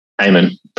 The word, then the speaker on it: Eamonn: Eamonn, very close.